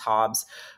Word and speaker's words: Hobbes: I say that wrong because I'm Canadian. I know it's Hobbes, but you know what I'm talking about.